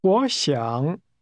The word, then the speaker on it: 我想: Would like is pronounced with a falling, rising tone. Say again, I would like. – 我想。